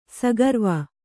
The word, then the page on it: ಸಗರ್ವ: sagarva